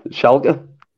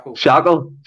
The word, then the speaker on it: Schalke: I know he's seen, I say this name, Schalke. What is it, Schalke? Because when I first pronounced it, I said, what was it I said first of all? – Shackle, shambles.